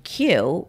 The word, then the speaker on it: Kyu: And for my solo dining experience this week, I went to a place called Q, which is spelled KYU.